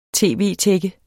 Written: tv-tække

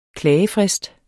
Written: klagefrist

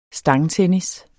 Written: Stangtennis

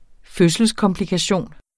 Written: fødselskomplikation

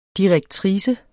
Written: direktrice

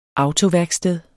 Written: autoværksted

